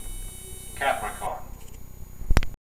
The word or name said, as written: Capricorn